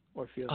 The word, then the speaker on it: Orphelia: Did I pronounce that right? – Yes, Orphelia.